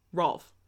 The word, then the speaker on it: Rolf: Ralph. It's Ralph. No. Yes. – Rolf. – Right. Like Wolf.